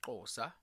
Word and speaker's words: Xhosa: The 12 official languages of South Africa are Sepedi, Sesotho, Setswana, IsiSwati, Tshivenda, Itsonga, Afrikaans, English, IsiNdebele, IsiXhosa, or it's actually pronounced Xhosa. I don't even know if I'm doing the clicking right.